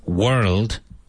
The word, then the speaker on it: world: World is not an easy word to pronounce because there are three consonant sound. There are three consonants at the end of the word, R-L-D. But if you pronounce it the way an English person does, which is world, it's a bit easier because you remove the R and it's perfectly understandable.